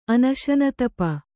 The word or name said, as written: ಅನಶನತಪ